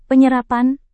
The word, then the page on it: penyerapan: Indonesian